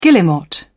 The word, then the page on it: guillemot: British English